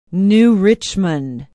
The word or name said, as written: New Richmond